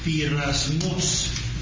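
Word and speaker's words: Pirasmos (πειρασμός): But say it once for them, so they can hear it the way it's supposed to be. – Pirasmos. – See what I mean by overtones? Pirasmon. – The action is on the ultima.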